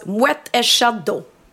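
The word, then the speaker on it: Moët & Chandon: Okej, du kan komma undan med det, men det uttalas Moët en Chandon.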